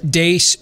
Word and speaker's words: Deace: You can like us on Facebook and follow us on Twitter at Steve Dace Show. Last name is spelled D-E-A-C-E.